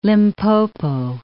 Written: Limpopo

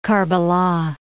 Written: Karbala'

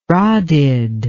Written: rodded

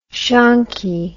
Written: shonky

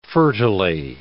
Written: fertilely

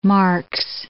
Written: Marx

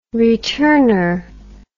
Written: returner

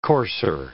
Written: courser

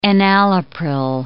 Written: enalapril

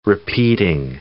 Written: repeating

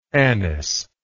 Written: anisic

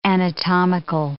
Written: anatomical

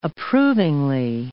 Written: approvingly

